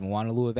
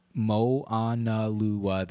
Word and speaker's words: Moanalua: Moanalua Valley. Moanalua Valley. Seven syllables.